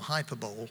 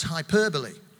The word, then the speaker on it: hyperbole: And that language device is something called hyperbole, which I mistakenly used to call hyperbole and made myself look a complete idiot. It's pronounced hyperbole.